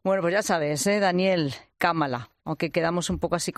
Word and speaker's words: Kamala: Por cierto, una cosita, Juan, que andamos siempre aquí los periodistas y todo el mundo en España, ¿cómo debemos pronunciar el nombre de Kamala Harris? ¿Kamala o Kamala? – Kamala, como Kamala. Si fuese una palabra estrújula, como si pusiésemos el acento en la Kamala.